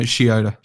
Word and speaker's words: Shioda: No, Shioda. – Shioda. Okay. – Yeah, it's like soda. It's like soda, Shioda. But it's she.